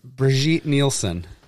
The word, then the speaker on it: Brigitte Nielsen: Brigitte Nielsen.